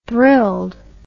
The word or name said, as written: thrilled